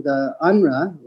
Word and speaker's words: UNRWA: UNRWA was spelled UNRWA. There's a silent W in there.